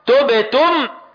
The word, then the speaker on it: تبتم: وتظهر مهارة القارئ عند توالي الحركات، فمثلا عند نطقنا تبتم نضم الشفتين اولا للنطق بالتاء المضمومة، ثم نرجع الشفتين لوضع السكون للنطق بالباء الساكنة، ثم نعود لضم الشفتين للنطق بالتاء الثانية المضمومة، ثم نعود لوضع السكون للنطق بالميم الساكنة.